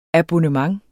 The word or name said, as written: abonnement